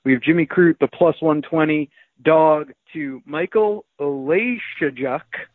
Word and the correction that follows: Oleksiejczuk: Oh, Oleksiejczuk. Just the emphasis was wrong, but apart from that, you did well.